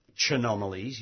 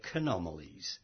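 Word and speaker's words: Chaenomeles: And, of course, when you see a CH in Latin, you're supposed to pronounce it as K. So you don't say Chanomalies, you say Canomalies.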